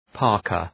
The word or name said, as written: parka